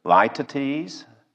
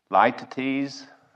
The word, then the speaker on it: litotes: Professor Carson clarifies the confusion a bit by identifying this as a litotes. Litotes. I had to look up the pronunciation. It's a figure of speech, L-I-T-O-T-E-S. It's a litotes.